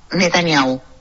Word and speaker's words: Netanyahu: ¿Cómo se dice Netanyahu o Netanyahu? – Netanyahu. – Netanyahu, Netanyahu. – Netanyahu, sí. Netanyahu.